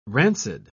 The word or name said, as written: rancid